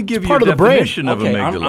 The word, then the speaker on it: amygdala: You know how to pronounce it? – I do. – Go for it. – I looked it up. – Okay. – Amygdala.